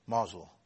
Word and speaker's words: Mosul: Mosul.